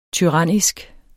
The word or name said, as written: TYRANNISKE